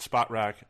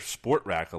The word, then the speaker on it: Spotrac: I've heard spot rack. I hear sport rack a lot, which is wrong because there's no R before the T.